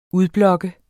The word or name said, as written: UDBLOKKER